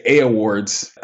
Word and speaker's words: Awwwards: I saw you got uh, Site of the Day from, how do you say, is it awards? Because it's like A-W-W-W-A-R-D-S. Do you just say awards? – I just say A awards.